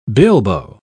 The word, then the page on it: bilbo: British English